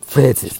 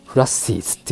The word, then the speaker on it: phrases: という不安を持ちながら、今日のイングリッシュキーフレーズですね、はい、 PHRASES フレーズ、これどう見てもフレーズって読めないですよね。キーフラッシーズって読んじゃいますよね。